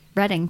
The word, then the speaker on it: Reading: It's Reading, PA. That pisses me off. – Spelled reading? – It's spelled reading. – Yeah, it's reading.